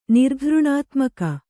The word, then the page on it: ನಿರ್ಘೃಣಾತ್ಮಕ: nirghřṇātmaka